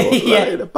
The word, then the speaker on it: Larraín: Pablo Lorraine. – It's going to be a lot of great stinger material like we did with the Denis Villeneuve. It'll be like Pablo Larraín.